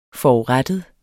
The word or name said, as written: FORURETTET